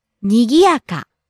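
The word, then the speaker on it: にぎやか: Nigiyaka. – Bustling, busy, lively. – Nigiyaka, nigiyaka. – Rising on the second syllable gi, coming down on the third syllable ya and staying low.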